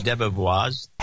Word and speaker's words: Debevoise: DeBevoise, is that how you pronounce it? DeBevoise? – DeBevoise has been a long-established leader in corporate litigation and large financial transactions.